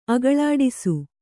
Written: ಅಗಳಾಡಿಸು

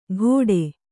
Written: ಘೋಡೆ

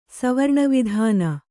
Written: ಸವರ್ಣವಿಧಾನ

